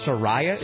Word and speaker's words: Soraya: Number four, Soraya. Love has taken its toll. – And you said it's actually Soraya. – Soraya, is that how it's pronounced? – Yeah.